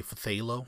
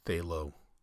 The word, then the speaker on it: phthalo: So originally I was going to say phthalo blue, but I know that Bob Ross calls these phthalo. I think he uses phthalo white.